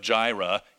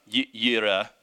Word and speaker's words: Yireh: That was probably supposed to be a silent J. You know, so instead of Jireh, Yireh.